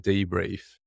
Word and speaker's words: debrief: That's because Angie has been pronouncing debrief with a TH instead of an F.